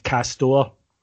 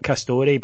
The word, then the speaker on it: Castore: I'm sorry to have to break it to you because I was labouring under this misapprehension as well that it's pronounced Castor, not Castore, but it was such a good line for the opening of the pod that I went with it.